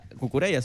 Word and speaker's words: Cucurella: Cucurella se pronuncia. – Cucurella le digo yo. – Al Madrid. – Cucuyuela.